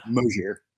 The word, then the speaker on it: Mosier: And I just butchered your last name. My bad, Alex. – Sorry. – Mosier. – So, yeah, Alex Mosier,